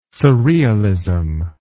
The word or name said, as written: surrealism